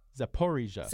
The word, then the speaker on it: Zaporizhia: Actually, in terms of the pronunciation, you were correct the first time, but the stress is on the po. – Zaporizhia.